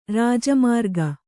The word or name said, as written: ಋಜುಮಾರ್ಗ